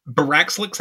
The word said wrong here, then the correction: Baxcalibur: Baxcalibur, sorry, Baxcalibur.